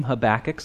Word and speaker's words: Habakkuk: So our reading today is from the prophet Habakkuk, and Gene did a great job pronouncing that name, Habakkuk, so good job, Gene.